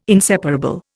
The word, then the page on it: inseparable: Indian